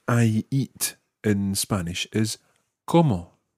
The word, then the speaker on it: como: No, the word como meaning how and the word como meaning I eat sound exactly the same. There is absolutely no difference in pronunciation.